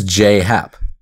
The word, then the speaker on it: J.A. Happ: he pronounces his name as J.A.Y., so J. – So it is J.Happ. – Yeah, it's J.Happ.